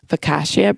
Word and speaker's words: focaccia: And then showed how people were making this focaccia bread.